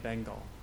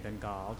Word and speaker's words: Bengal: it fills the entire Bay of Bengal, or Bay of Bengal, depending how you say it.